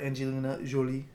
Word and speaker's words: Angelina Jolie: Sway was written especially for Angelina Jolie.